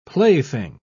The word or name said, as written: plaything